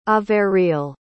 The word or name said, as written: averill